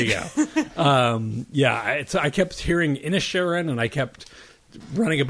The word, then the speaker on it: Inisherin: I only went with Irish pronunciations and the internet pronunciations, which would have been Inisherin, an E instead of an A, and it could be the dialect of over there in County Galway.